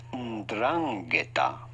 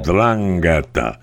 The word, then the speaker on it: 'Ndrangheta: I think that's the right way to pronounce it. I don't want to get shot by them if it's the wrong way. – 'Ndrangheta. – Yeah, that's right. Okay, 'Ndrangheta.